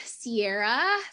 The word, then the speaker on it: Cierra: Yeah, you can find me on Cierra, spelled with a C, C-I-E-R-R-A, Danielle Jackson, my whole government name.